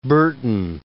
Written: Burton

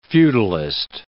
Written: feudalist

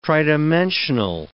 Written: tridimensional

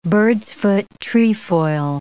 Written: bird's-foot trefoil

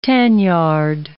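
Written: tanzanite

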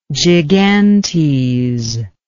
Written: gigantes

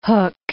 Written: Hook of Holland

